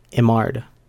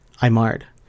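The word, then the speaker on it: Eymard: It's Saint Peter Julian Imard. And if you're curious on how that's spelled, it's E-Y-M-A-R-D. Saint Peter Julian Imard.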